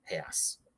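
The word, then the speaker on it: reais: So when you go to Brazil, it says, you look at it in English, it says, why is it called real? And then it's actually pronounced heas.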